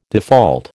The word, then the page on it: default: American English